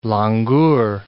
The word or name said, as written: langur